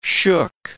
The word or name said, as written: shook